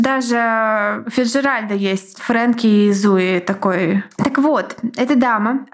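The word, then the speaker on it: Zooey: Эмоджи «Рыжая женщина», если Эмили круче, и черноволосая женщина, если Зуи круче, если ты настаиваешь Конечно, так произносить. Два О у нее в имени. – Да хорошо, я, я не спорю, просто я только от тебя услышал Зуи.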